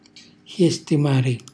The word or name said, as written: xjestimari